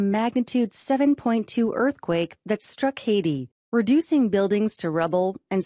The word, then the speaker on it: Haiti: 영어로는 Haiti라고 읽고요.